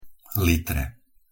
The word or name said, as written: litre